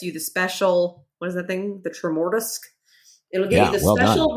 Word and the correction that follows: Tremortusk: I think it's Tremortusk. Really, yes. – Is it? Tremortusk. I'm sorry. That is definitely that. I'm an idiot. Tremortusk.